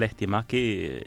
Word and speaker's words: Lehtimäki: Muy buenos días, el apellido ha salido bien, Lehtimäki, bien pronunciado, justo como debe ser.